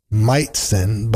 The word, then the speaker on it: Majcen: We're going to keep things going now with one from Leon Meitzen, a strange last name, a bit easy to pronounce if you Google it, Meitzen, but it's spelt a little differently. Majcen is how you spell it,